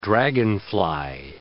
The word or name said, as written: dragonfly